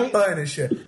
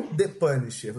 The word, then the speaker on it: The Punisher: Não é The Punisher. É o The Punisher.